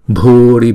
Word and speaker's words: bhuri: Chữ Bhu là kéo dài hai nhịp, và chữ B với chữ H ghép lại là Bh, Bhuri, Bhuri.